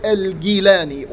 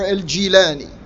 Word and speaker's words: al-Gilani: or shows an emotional attachment to Abd al-Qadir al-Gilani, or al-Gilani. This is pronounced both ways, depends on what part of the world you're from. Or another such figure in Islamic history.